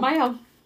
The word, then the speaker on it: Mielle: All of us use Mayo. – Mayo. – Yep. So, Mayo, hit up Frida, okay? Because she getting y'all money. But Mayo's Instagram is at Mayo Organics, and Mayo is spelled M-I-E-L-L-E, Organics.